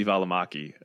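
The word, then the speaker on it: Välimäki: But does the average Joe Schmo want to watch Jake Knapp and Sam Valbanaki? Is that how you pronounce his last name? – That was, is that terrible? – You somehow Americanized Sammy Valamaki. It was like Sam Valamanaki. Yeah.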